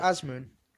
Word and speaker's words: Azmoun: I can't say Sardar Azmoun.